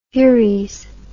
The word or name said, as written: furies